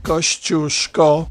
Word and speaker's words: Kosciuszko: Polish explorer Paul de Strzelecki climbed and named Mount Kosciuszko, which is pronounced Kosciuszko.